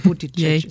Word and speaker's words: Buttigieg: Aber Sie haben ihn ja schon ganz richtig ausgesprochen. Buttigieg ist richtig.